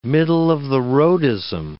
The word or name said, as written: middle-of-the-roadism